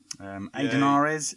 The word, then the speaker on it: Aidan Ares: We have had one submitted from Adernary's DMT. Oh, fuck's sake, I've mispronounced his name. So, we've come to that point in the show where we have Argue the Inarguable. Aidan Ares, DMT.